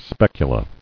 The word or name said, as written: Specula